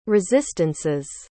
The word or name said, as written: resistances